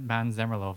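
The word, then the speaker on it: Måns Zelmerlöw: Mans Zemmerlo was one of the hosts with Petra something. – You said Mans Zemmerlo very Swedishly there. You're like Mans Zemmerlo.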